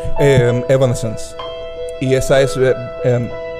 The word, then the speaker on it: Evanescence: Eh, eh, Evanescence. Sí, Evanescence.